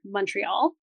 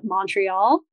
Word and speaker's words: Montreal: We say it more like, and this is if you're Anglo-Canadian, we say like M-U-N, like Montreal. And I know that Americans tend to say more like a long O or an A, like Montreal.